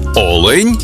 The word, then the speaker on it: олень: в однині і в множині наголос падає на перший склад – олень та олені.